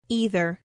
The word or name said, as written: either